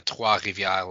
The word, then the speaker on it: Trois-Rivières: The new team out of Quebec is the Trois-Rivières Lions, or Lyon, I guess, if you're French.